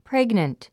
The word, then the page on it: pregnant: préɡnənt; プレグナント